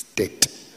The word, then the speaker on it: debt: Until one of the English ladies came to me, she said, it is a silent B. It's debt.